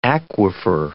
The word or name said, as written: aquifer